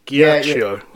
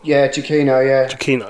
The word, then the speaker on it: Giacchino: Was it Giacchino? – I think Michael Giacchino. – Giacchino. – Oh, that's how you pronounce it.